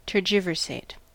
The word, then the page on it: tergiversate: American English